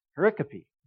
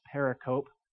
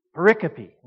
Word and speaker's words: pericope: This is what they taught me in seminary, so here's what my whole seminary tuition went to to learn this fancy word, pericope. Sometimes you'll hear it mispronounced pericope. That's a student that wasn't paying attention. Pericope.